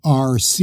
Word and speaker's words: ARC: And it's pronounced by stating its letters, A-R-C.